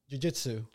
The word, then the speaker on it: jiu-jitsu: Do jiu-jitsu? Am I pronouncing that correctly? – Jiu-jitsu.